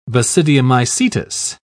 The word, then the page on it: basidiomycetous: British English